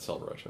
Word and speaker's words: Selvaraj: I got it, Selvaraj.